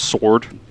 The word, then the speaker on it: Soard: His last name was S-O-A-R-D. It's pronounced Sword.